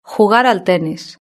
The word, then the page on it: jugar al tenis: European Spanish